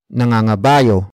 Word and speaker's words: nangangabayo: Word for word, it's nangangabayo, ang, lolo, ko, noong, araw.